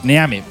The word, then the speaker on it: Neame: Pues léelo en español, te lo leo yo. Seper Neame. Neame. Claro, así no fallas.